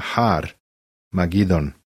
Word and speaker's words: Armagedon: stih, na mesto koje se jevrejski zove Armagedon, jevrejski se izgovara Har, Magidon.